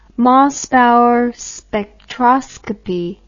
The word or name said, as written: Mossbauer spectroscopy